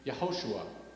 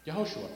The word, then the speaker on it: Yehoshua: What's interesting is Joshua is actually pronounced Yeshua, and in Hebrew, it's a shortened version of the name Yehoshua. Yehoshua.